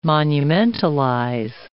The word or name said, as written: monumentalize